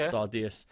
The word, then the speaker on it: Stardeus: It's spelt like Star Juice, but it's called Stardeus. Space engineers.